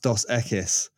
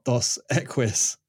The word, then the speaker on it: Dos Equis: And he said, mate, it's pronounced Dos Equis, not Dos Equis.